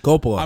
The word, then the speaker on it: Coppola: Coppola. – I'm really not trying to be funny when I do that, but I can't. Okay. – It's Coppola. I mean, that's the way I've heard it pronounced.